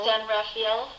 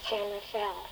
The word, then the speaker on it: San Rafael: San Rafael? – No, San Rafael. – Rafael. I didn't even know. No. Everybody has a different pronunciation. – Oh. Okay. San Rafael.